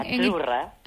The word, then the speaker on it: Azzurra: Azzurra. – Azzurra. Mira, ¿ves? – Azzurra. Es que... Sí, como porque pizza, la Z no es la Z como decimos en catalán tan sonora, no.